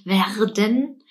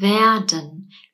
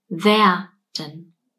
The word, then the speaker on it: werden: Wir haben ein R. Das sagen wir nicht als R. Wir sagen nicht werden. Wir sagen werden. Wir sprechen es wie ein kurzes A aus. R, wer, werden. Die Betonung liegt auf der ersten Silbe. Werden.